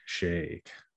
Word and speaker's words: Shake: Today, we are going to be featuring Corey Shake. What's up, brother? – What's up? I love how you say it. It's nice. I'm so used to chic in, like, you know, in white people Canadian town, but yeah, it's nice.